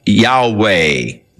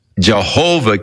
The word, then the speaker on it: Yahweh: So the general consensus of opinion is that the name was to be pronounced Yahweh. But somewhere along the line, the pronunciation of Jehovah came along, or Yehovah, and it has become more popular, but Yahweh is probably the correct pronunciation, though we do not know for sure.